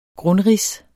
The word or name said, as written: grundrids